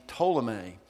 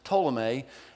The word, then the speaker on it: Ptolemy: There was, for 1,200 years, people in the world saw the universe a certain way because there was a man named Ptolemy, and it's spelled P-T at the beginning. The P is silent. His name's Ptolemy.